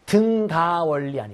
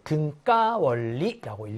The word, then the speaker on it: 등가 원리: 등가 원리 아니고 등가 원리라고 읽어주시고요.